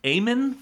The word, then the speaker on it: Amen: So it is, Super A Foods is run by the, Amen family. – Okay. – Amen. It's spelled like Amen. – Okay. A-M-E-N. – A-M-E-N.